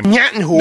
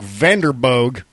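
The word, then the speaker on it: Vanderboegh: How do you pronounce his name? – Nyattenhoek. – No, that's not how you do it. It's Mike Vanderboog.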